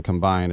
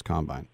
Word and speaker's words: combine: And remember, in the NFL, the emphasis is on the second syllable. It's not offense, defense, and combine. It's offense, defense, combine.